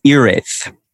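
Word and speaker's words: Hiraeth: Is it Hira-eth or Hira-eth? – Oh, yeah. So that, you know, is up to debate. I the pronunciation I have been told is Ireth.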